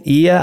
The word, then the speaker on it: Oia: And if you see this city and you're like, I don't know what the heck you're saying, Ia, I don't see that, like on the map, OIA is how that one is spelled.